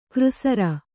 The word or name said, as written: ಕೃಸರಿ